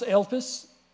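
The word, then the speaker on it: elpis (ἐλπίς): The Greek word is pronounced elpis, to anticipate with complete confidence, to look forward to with complete confidence.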